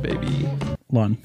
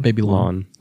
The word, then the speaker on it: Babylon: I like that Showbiz, Baby Long. – Long. – Baby Long or Baby Lawn? – Baby Lawn. – That's Showbiz, Baby Lawn. – And you spell it Babylon.